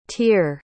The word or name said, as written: tyr